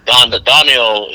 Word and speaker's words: Donnell: And Donnell is pronounced O'Donnell.